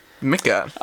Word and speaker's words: Mika: And then he or she responded, I hope this is Mika, M-I-K-A. – Oh, Mika. – Mika. – I think it's pronounced Mika.